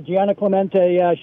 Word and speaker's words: Gianna Clemente: And someone you were writing about, Gianna Clemente, is that how you pronounce her name? – Yeah, Gianna Clemente, she's remarkable.